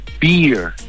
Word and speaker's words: beer: Y este hace, ya le dice el camarero a mi amigo, le dice, no, you want a beer. Porque con, o sea, la barba se pronuncia beard, el oso se pronuncia bear, y la cerveza se pronuncia beer. Pero con R al final.